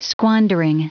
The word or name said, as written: squandering